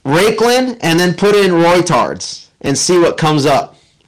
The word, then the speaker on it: Reuters: Yeah, it's pronounced, well, it's spelled R-E-U-T-E-R-S, but it's pronounced Reuters, and some people pronounce it without the U.